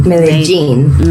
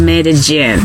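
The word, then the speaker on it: Medellín: I think it's gin, not yin, right? – Yes, it's actually gin. Medellin. – Medellin. – Yeah. – It's not even lin.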